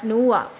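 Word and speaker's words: noir: And similarly film നൂവ, film നൂവ N O I R pronounced as Nuwa, film Nuwa, can be seen to have crossed the border supposedly separating popular and high culture. Film Nuwa, Nuwa is a French word which means dark film.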